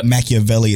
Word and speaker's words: Machiavelli: See, the thing, what made How to Real as Hero Rebuild the Kingdom so fucking phenomenal too was the Machiavelli close. Or Machiavelli is how you pronounce it in their home country or whatever.